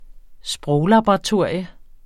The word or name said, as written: sproglaboratorier